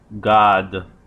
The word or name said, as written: god